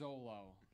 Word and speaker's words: Xolo: No, the Zolo. I might say it wrong. XOLO. Look it up. What did I say? – I can't. – What did I say? – Between you and him. – Look it up. XO. Yeah. Am I lying? – It's a Zolo.